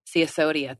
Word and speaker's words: Sisodia: Sisodia.